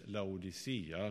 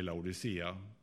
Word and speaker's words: Laodicea: Och den 7:e församlingen, det är den församlingen i Laodicea, eller Laodikeia, hur den uttalas. Jag säger Laodicea.